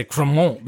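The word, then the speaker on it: Crémant: And it's spelled Cremant, but I say Cremant because I'm trying to say it in the French way.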